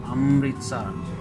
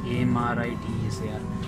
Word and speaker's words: Amritsar: I believe it's pronounced Amritsar. It's Amritsar.